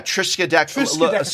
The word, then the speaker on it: Triskaidekaphobia: Trodeskiphobia? I could never pronounce it then, but yeah, I know. – Yeah, Triskaidekaphobia, so that's it.